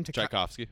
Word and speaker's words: Tchaikovsky: Tchaikovsky.